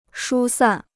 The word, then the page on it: 疏散: shū sàn